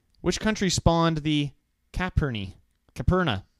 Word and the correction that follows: Caipirinha: Ooh, I don't know how to pronounce that. – What? – Caipirinha. It's a drink. – Okay. – Caipirinha. – The Campari? – No, there's no M in this.